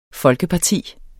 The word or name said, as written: folkeparti